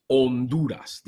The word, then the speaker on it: Honduras: So a little tip, when there's an H, the H is silent. So Honduras is Honduras.